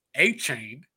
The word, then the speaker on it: Achane: And then they have Devin Achane, I believe is how you say it, from Texas A&M, you know, who looked great during the preseason, even had a 38-yard kickoff return.